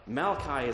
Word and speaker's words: Malachi: Malachi is actually the correct pronunciation.